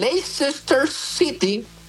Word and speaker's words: Leicester City: Vámonos hasta Inglaterra, donde el Leicester City.